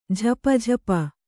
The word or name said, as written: ಝಪಝಪ